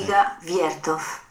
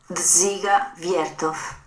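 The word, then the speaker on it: Dziga Vertov: Dziga Wiertow. – Okay. – Whatever that was. – Here, I'll play it again. – Dziga Wiertow.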